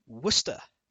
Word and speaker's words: Worcester: I've left off from the list, Worcester, the home of Worcestershire sauce.